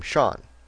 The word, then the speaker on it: Shawn: Sean. Now, Sean is spelled S-H-A-W-N.